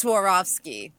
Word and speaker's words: Swarovski: Yes, it's Swarovski.